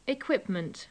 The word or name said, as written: equipment